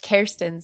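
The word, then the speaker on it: Kerstin: Yeah, it's Kirsten. So like Care Bears or carrots, yes. or yes. as your phone will correct you, carotene.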